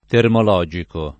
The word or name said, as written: termologico